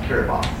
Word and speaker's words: Kiribati: So I thought of Kiribati, pronounced Kiribati, where Kiribati does not even have its own military, it has a police force, because it doesn't deal with a state threat.